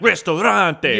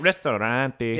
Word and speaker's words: ristorante: So you got to say it like, Ristorante. Yes. Not like, Ristorante. – Yeah, because that's Spanish.